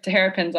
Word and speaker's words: Terrapins: And they would like give me gear, like shirts with Terrapins all the time. That's so funny. Probably not saying it right. – No, you said it right. You nailed it.